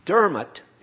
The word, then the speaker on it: Diarmaid: Dermot is spelled D-I-A-R-M-A-I-D. And I was calling him Diarmaid. But Mike Clardy said it's Dermot, like D-E-R-M-O-T.